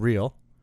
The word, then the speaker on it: Real: Also, the Major League Soccer team in Utah, Salt Lake City, they're called Real Salt Lake, spelled real, pronounced Real, and that comes from the Spanish Premier League's España, and it really just means royal, so Royal Salt Lake, even though there's no royalty there.